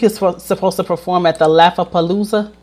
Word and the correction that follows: Lollapalooza: Did I say that right? The Lollapalooza.